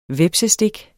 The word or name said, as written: hvepsestik